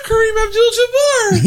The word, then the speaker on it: Kareem Abdul-Jabbar: Yeah, Kareem Abdul-Jabbar.